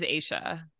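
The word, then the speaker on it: Aesha: How do you say... – Aisha. Yeah, it is Aisha.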